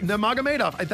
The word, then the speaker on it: Nurmagomedov: Side number two, Khabib Nurmagomedov wants, says, what's his last name? – Nurmagomedov. – I don't know how to pronounce it either. – It's Nurmagomedov. Like, I believe. – Nurmagomedov. That's close. Yes. I think that's pretty much it.